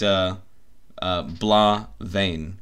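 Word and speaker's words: Blà Bheinn: I'll fucking spam you with some photos of like, because like, if you're on the maps there, then I hiked Blaa Vein, which is spelled BlaBheinn. But yeah, so I see that here.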